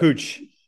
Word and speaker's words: Puig: Ricky Puig, though, from the spot, help my pronunciation there. Pooj, I believe, is what. – Pooch. – Pooch, yeah. – Thank you. I said it like Yasiel Puig, the former Dodgers player, the Cuban player, and Tony Husband got it right, and so did you.